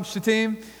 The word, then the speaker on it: Shittim: And be careful how you pronounce that word, okay? It's a long I, okay? So, yeah. Anyways. Then Joshua, son of Nun, secretly sent spies from Shittim.